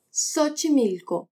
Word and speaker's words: Xochimilco: Y por fin suena como S al principio de una palabra, como en Xochimilco. Habla Xochimilco. Además de la pronunciación hemos visto que reírse de y reírse con tienen sentidos muy distintos.